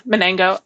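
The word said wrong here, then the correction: SALESmanago: It is sales manago.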